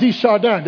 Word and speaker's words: de Chardin: The philosopher Richard Teilhard de Chardin, did I say that right? De Chardin? Yeah, there you go.